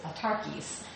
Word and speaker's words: autarkes: A-U-T-A. And then R, K-I-E-S. So you actually pronounce it autarkes.